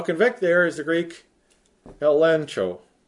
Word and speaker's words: elencho: And in the Greek, it's spelled E-L-E-G-C-H-O, but since the Greeks found it impossible to pronounce G-C-H, they would pronounce it N-C-H. So you might as well spell it that way. If you spell it with a G, you wouldn't know how to pronounce it. So spell it elencho.